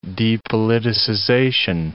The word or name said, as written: depoliticization